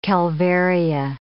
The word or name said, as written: calvaria